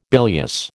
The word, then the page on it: bilious: American English